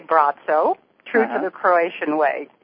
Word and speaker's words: Braco: And just to let you know and your listeners know, his name in Croatian is pronounced Braco. – Oh, even though in English we would look at it and say Braco.